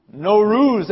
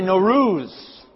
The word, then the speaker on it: Nowruz: Nowruz. Everyone say Nowruz.